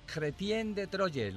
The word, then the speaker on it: Chrétien de Troyes: Os presento, queridos amigos, a un clérigo llamado Chrétien de Troyes. Lo digo como se lee en francés porque mi pronunciación es la que es.